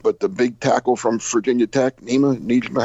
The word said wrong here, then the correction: Nijman: how do you say his last name? – Yosh Nijman.